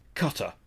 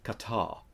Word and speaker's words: Qatar: It is not Qatar, just for our American listeners, it isn't. You're not cutting anything, it is Qatar.